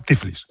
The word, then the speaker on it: Tiflis: Desde Tiflis, la capital, o Tiflis, no sé cómo se dice ahora. – Castellano, Tiflis. – Tiflis se dice, vale.